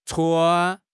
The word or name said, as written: chuan1